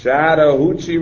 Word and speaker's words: Chattahoochee: Chattahoochee River.